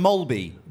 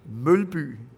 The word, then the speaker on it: Mølby: Pronounce that for me, because I always said Jan Mølby. – Mølby. – Okay, wait a minute. Let me try to do it. Mølby. – Mølby. Really like short and flat.